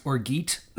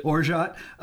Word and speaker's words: orgeat: But I have another bourbon, and I still have some of the El Silencio Mezcal, and this has the Orgeat sweetener, which I think at the time I pronounced Orgeat, but it's actually Orgeat.